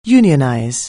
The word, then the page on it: unionize: anglais